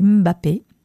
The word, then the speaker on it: Mbappé: Et puis là, bah voilà, notre Kylian Mbappé. Non, c'est pas comme ça, c'est Mbappé, la prononciation, et lui, c'est la pépite.